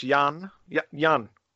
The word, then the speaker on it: Jan: Thank you very much, Jan. Jan. – Thank you, Jan. – Short A, Jan. – Yeah, Jan. Yeah. It's a typical name here in Hamburg.